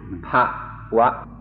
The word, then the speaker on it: bhava: This This word that I pronounced, bhava, is spelled B-H-A-V-A, and my pronunciation has been corrected to pa-wa. If I make it pa-wa, that's a different word with a different meaning. So I'll try to say it properly. Pa-wa, pop, or in Thai, it's very easy, pop.